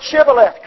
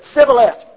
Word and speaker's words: Shibboleth: They could not say Shibboleth. They were told, say the word Shibboleth. Couldn't do it. Said Sibboleth. Well, he's dead.